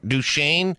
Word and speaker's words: Duchesne: Fort Duchesne. Duchesne? – Yeah. – Fort Duchesne? – Mm-hmm. – Utah. It is in the northeast part of the state. Fort Duchesne, Utah.